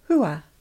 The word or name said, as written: Hua